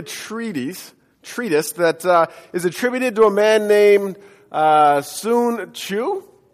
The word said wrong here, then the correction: Tzu: Anybody know how to pronounce that? Tzu. Okay, good.